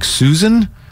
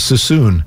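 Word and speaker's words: Suisun: Why do Susun City is pronounced like Susan? I'm from Vallejo. I thought it was Susun. Am I wrong? Is it Susan? I've never heard it Susun City.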